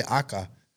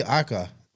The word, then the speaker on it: Aka: The Spanish guys have been saying the name right all this time. They say Aka. – Yeah. – It's really Aka. Really? – Japanese for red.